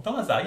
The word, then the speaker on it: Dolezal: Am I saying a name wrong? Dolezal? – Dolezal, yeah, that's her name.